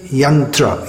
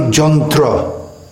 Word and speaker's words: yantra: In Sanskrit, yantra, yantram. Bengali, jantra. The y becomes j and the a becomes a.